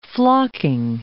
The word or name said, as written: flocking